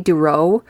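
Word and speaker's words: Dorough: You know, his actual name is Howie, and it's probably Howie Duroe, but it's spelt Duruff.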